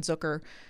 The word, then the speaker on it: Zucker: Jason Zucker rhymes with hooker.